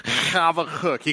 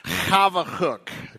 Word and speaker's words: Habakkuk: Habakkuk. Somebody say it. Habakkuk. The Hebrew is this: Habakkuk. Say that with me. Habakkuk. You got to go when you start. Habakkuk.